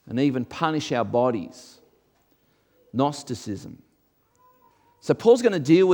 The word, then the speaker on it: Gnosticism: Silent G, Gnosticism.